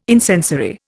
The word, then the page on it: incensory: Indian